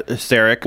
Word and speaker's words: Szarekh: Just silent Z. – Usually, you just call him the Silent King. – No, I meant the Z. – The Z, because it's spelled S Z A R E K H. Silent Z, silent H.